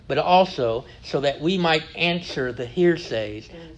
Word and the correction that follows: heresies: the heresies, I always say that wrong, the heresies that are found among us today.